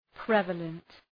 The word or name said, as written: prevalent